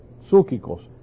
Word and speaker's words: psuchikos: Aquí vemos que el hombre natural es la palabra, en el griego es la palabra psukikos. Psukikos. Y se escribe así en español. P-S-U-C-H-I-K-O-S. Psukikos.